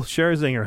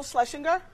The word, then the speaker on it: Scherzinger: Nicole Scherzinger has taken to TikTok to react to Wendy Williams constantly pronouncing her name wrong. Here's what she said. – Nicole Schlesinger.